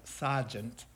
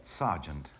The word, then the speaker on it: sergeant: He emigrated to Israel and served in the armed forces for 9 years, retired as a sergeant. How do you say it? – Sergeant. – Sergeant.